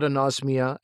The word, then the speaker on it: anosmia: I know about anosmia.